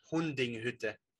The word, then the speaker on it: Hundinghütte: Oh, okay, I didn't know if the E is silent, because there's the yeah, it is. The U. Yeah, it is just Hundinghütte.